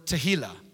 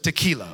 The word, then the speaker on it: tehillah: That word praise in the Hebrew is the word tehillah. Make sure you pronounce that one correctly as well. No, you do not enter into his presence with tequila, okay?